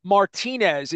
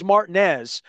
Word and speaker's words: Martinez: and they don't call it Martinez, even though it's spelled like Dennis Martinez or Tippy Martinez. It's Martinez, Georgia, and it's right next to the golf course.